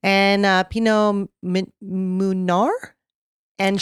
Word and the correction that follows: Meunier: How do you spell it? – M-E-U-N-I-E-R. – M-E-U-N. – Mounir or Mounir. – Menoir? – Menoir? – Yeah. Because O-I-R in French is our. – Let's see if it'll do it. – Is our. Are we going to have the computer tell us? – Pinot Meunier. – Pinot Meunier. – There you go.